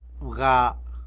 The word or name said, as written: wga